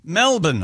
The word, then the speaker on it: Melbourne: And the San Francisco 49ers routed the Los Angeles Rams 27-7 last night, or this morning, or whatever time it was, in Melbourne, Australia, or how do we say it? – Melbourne, Australia.